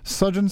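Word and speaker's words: Sufjan: And then how do you pronounce that guy? – It's Sujan Stevens, saving me from further embarrassment.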